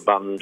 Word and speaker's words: Bund: And they had lots of clubs and cabarets and things like that going on around the Bund, which is a funny pronunciation, but that's how they say it, the Bund, that's right.